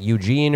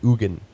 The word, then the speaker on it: Eugen: And I thought his name, and it would be perfect because his first name is spelled E-U-G-E-N. So for a while I thought it was just like Eugene or Ugin, Eugen, which would be like maybe eugenics was named after him. But no, it's the Swiss pronounce it Ugin.